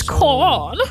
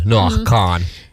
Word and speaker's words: Kahan: Hyvä, vomma ton mulle opetti, kun mäkin oon aina sanon Noah Kahan, ja sit vomma oli vaan I don't want to be this guy, but it's Kahan.